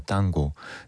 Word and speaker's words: tango: 아르헨티나에서는 우리가 흔히 얘기하는 탱고, 이 오리지널 발음은 땅고입니다. 땅고.